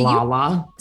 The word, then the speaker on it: Lala: I've loved Layla on your show. I mean, to some degree, Lala.